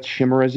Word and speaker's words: chimeras: Is that chimeras, you guys? I always miss that. Yeah, I think so. Yeah, I think that's how it's pronounced.